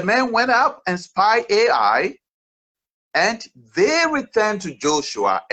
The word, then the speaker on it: Ai: Ai is spelled A plus I, Ai.